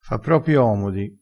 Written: fa propi omodi

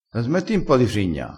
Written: frigna

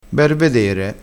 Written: bervedere

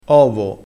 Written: ovo

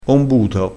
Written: ombuto

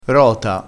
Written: rota

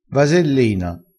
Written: vasellina

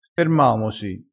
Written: fermamosi

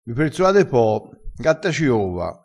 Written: gatta ciova

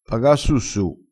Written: paga su su

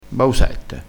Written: bausette

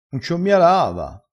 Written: un avecci

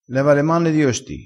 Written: leva

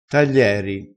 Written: taglieri

